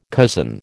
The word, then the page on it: cousin: American English